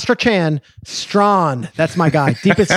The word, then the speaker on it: Strachan: It's the way that I remember it is it's like how the word draft is spelled in Europe, like draught. – Yeah. – That's how I think of Strawn.